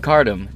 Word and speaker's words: Khartoum: In the capital city of Khartoum,